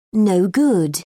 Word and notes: no-good: British English

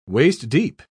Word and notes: waist-deep: British English

